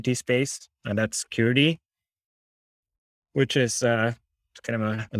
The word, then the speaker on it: Curity: Since it's a Swedish company, it's SE, it's the country code. So it's SE.curity.